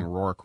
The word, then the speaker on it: Roark: And yes, he does pronounce it that way. We're not saying Roark wrong. He does say Roark.